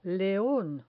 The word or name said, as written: leon